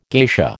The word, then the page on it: geisha: American English